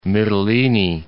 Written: Mytilene

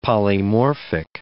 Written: polymorphic

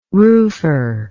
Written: roofer

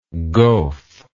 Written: goaf

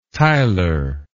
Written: tiler

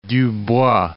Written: Dubois

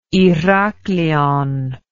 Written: Herakleion

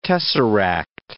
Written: tesseract

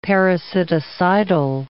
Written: parasiticidal

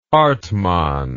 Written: hartmann

